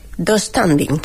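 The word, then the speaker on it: döstädning: La práctica, no sé cómo se dirá, ¿vale? Yo lo pronuncio... Dos standing. – Sí, así.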